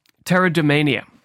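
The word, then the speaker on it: pteridomania: And this craze for ferns was called pterodomania, with a P, a silent P, like pneumonia.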